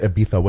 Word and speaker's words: Ibiza: Is that what you mean by Ibiza? – No, it's Catalan. In Catalan, the Z is pronounced with a th.